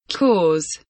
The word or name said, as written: cause